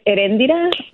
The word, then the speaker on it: Eréndira: It's Eréndira, but I go by Ere.